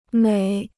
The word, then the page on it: 每: měi